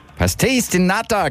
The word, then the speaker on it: Pastéis de Nata: Und die Aussprache jetzt wird ein bisschen Pastéis de Nata. – Pastéis de Nata, genau, das ist aus Portugal.